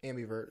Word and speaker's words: ambivert: Ambivert.